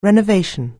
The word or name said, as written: renovation